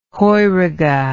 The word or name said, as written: heurige